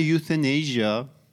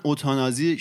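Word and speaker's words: euthanasia: توی انگلیسی بهش میگن یوثنیزیا که یه لغت ریشه یونانی داره به معنی مرگ خوبه. حالا تو فارسی من اوتانازی شنیدم که یه مدل دیگه از تلفظ همین لغته، ولی به فارسی هم ترجمه شده، ترجمه قشنگی هم داره که مثلا مرگ آسان، بهمرگی، مرگ شیرین، مرگ باوقار.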